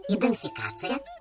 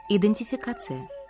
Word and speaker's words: идентификация: Вот, например, такие «скабрёзный», «чесунчёвый», «идентификация». – Вот вы все слова произнесли неправильно. – Неправильно? – Надо так. «Скабрёзный», «чесунчёвый», «идентификация».